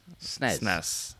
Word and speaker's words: SNES: I also It's not a hard SNES. S on the end. It's a soft S. SNES.